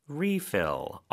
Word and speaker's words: refill: Refill. R-E-F-I-L-L. – 네, 뭐 음료수 리필도 refill. 이렇게 F 발음으로 정확하게 해주셔야 되겠죠.